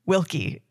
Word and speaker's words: Wilcke: So excited to have him, Sam Wilcke. Did I say your last name right? – You said it perfectly.